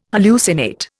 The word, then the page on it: hallucinate: Indian